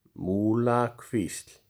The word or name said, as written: Múlakvísl